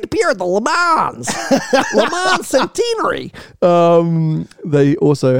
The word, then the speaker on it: Le Mans: It's not Le Mans, because it's, I don't think, hey, I'm not French, but it's not Les Mans, and you don't pronounce the S at the end, it's Le Mans.